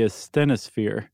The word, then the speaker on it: asthenosphere: And they float on top of the asthenosphere.